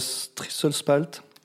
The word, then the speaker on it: Strisselspalt: Le Saz, le Strisselspalt. Mince, je l'ai tellement révisé que je pensais l'avoir bien prononcé je l'ai mal prononcé. Non, il était bien, il était bien.